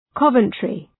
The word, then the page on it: Coventry: ‘kʌvəntrı